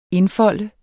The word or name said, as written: indfolde